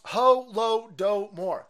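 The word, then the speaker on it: Holodomor: Okay, uh, it's pronounced ho lo do more.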